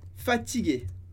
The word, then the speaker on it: fatigué: Se fosse uma moça, je ne suis pas fatigué. Pronuncia da mesma maneira com um E no final.